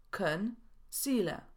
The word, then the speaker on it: concealer: W słowie korektor również są dwie sylaby. Concealer. I mówimy concealer, a nie concealer.